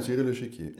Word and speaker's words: La Financière de l'Echiquier: La Financière de l'Echiquier heißen die, richtig? Mhm. Habe ich es jetzt richtig ausgesprochen? La ja, Financière de l'Echiquier.